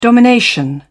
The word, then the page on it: domination: British English